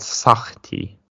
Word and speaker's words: Sahti: It's called Sahti, S-A-H-T-I. The H is kind of a like a Hebrewish H pronunciation. So it's Sahti.